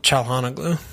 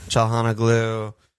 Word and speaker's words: Çalhanoğlu: How do you say his name? – Çalhanoğlu. – Çalhanoğlu.